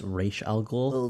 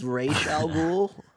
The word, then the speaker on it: Ra's al Ghul: I believe it's pronounced Ra's al Ghul. – It's Ra's al Ghul.